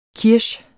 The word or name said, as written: kirsch